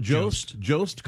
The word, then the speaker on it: Jost: Am I saying it right? Jost.